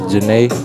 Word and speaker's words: Zhane: Yeah, if y'all looking for it, and in the audience, it's pronounced Janae. They spelled out the pronunciation on the album, but the artist is spelled Z-H-A-N-E, Janae.